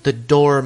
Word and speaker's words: dour: I learned something from this exchange because one of them, Mr. Beeb or somebody, refers to him as the door Mr. Emerson, which is the actual original English way of pronouncing what I always pronounce as dour. – Mm-hmm. – D-O-U-R. And apparently that's still, like, sort of the official pronunciation, although dour is considered, you know, standard and acceptable as well.